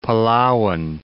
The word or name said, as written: Palauan